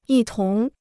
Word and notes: 一同: yī tóng

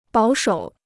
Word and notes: 保守: bǎo shǒu

